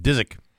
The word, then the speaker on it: Dziczek: I don't even know how do you pronounce that? D-Z-I-C-Z-E-K. Dizek? – Dizek.